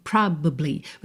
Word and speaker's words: probably: Mas se você quiser falar as três sílabas, você tem probably. Você praticamente quica no B. Tá? Porque você não fala probably, tá? Esse A não aparece quase nada mesmo.